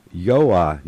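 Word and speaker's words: Joa: Speaking of translated words, the editor of the pilot is listed in the masthead as Yoa Nagy.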